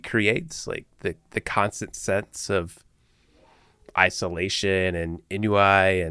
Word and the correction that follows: ennui: and is it inui? Inui? How do you say that? – Ennui. – Ennui. Wasn't even close.